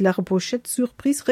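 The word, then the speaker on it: La Pochette Surprise: Ich würde mal sagen, man spricht es komplett französisch aus. Wild wird la Repochette sur Prise Record, sagt man so.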